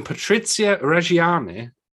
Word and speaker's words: Patrizia Reggiani: When Patrizia Reggiani, have I said that right? – Yes.